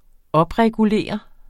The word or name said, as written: opregulere